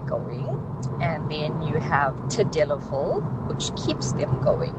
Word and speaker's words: Tadalafil: Hey Tabu, those two ingredients are called Sildenafil and Tadalafil.